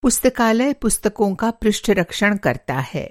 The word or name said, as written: पृष्ठरक्षण